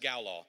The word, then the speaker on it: galal: And it's spelled like gala apples. We would know that, gala apples, but it's pronounced galal.